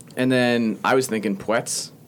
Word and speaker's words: Puetz: Tennis players, Ben Schmude and Tommy Puetz, which we're just finding out that's how you pronounce his last name, despite it being spelled P-U-E-T-Z, because we thought Tommy Puetz, which would have been...